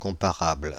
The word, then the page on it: comparable: Paris; [kɔ̃.pa.ʁabl]